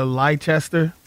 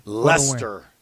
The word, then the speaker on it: Leicester: I'm going with Tottenham over Leicester. Money line. – Leicester, Jeff. Leicester. – Leicester? – That's how they say it, bro. I don't make the rules.